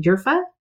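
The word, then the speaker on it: urfa: I also got excited about the idea of, I think I'm going to say it incorrectly, yerfa, the like black spice. – Yes. I think that's right.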